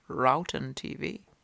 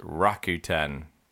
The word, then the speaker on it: Rakuten: By the way, that's pronounced Rakuten. – Okay. How do you say it was pronounced? Rakuten. – Rakuten. – Rakuten. – Yeah. – Apparently it's not Rowton TV, it's Rakuten TV.